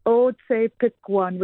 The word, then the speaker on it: Otsepikwan: So the best that I know how to say it as a native English speaker is Otsepikwan, which means it has roots in Plains Cree.